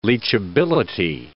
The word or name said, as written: leachability